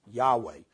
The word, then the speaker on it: Yahweh: So there's very close relationship when God says I am has sent you, and the Tetragrammaton pronounced Yahweh.